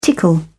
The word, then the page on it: tickle: British English